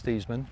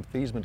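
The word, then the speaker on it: Theismann: We think by just simply changing the pronunciation of your name from Thiesman to Thiesman to rhyme with Heisman, we can help you get that trophy.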